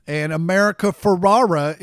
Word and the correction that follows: Ferrera: is leaving. – America Ferrera. – All right. – That's how you say her name.